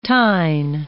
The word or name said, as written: tine